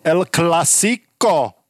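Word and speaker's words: El Clásico: El Clasico. Dobre som to vyslovil? Áno.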